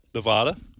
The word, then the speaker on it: Nevada: So Nevada, how do you guys pronounce that? – Nevada. – Not Nevada? – Just like you did.